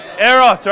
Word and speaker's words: Eros: Eros, right? It sounds like the word erotic.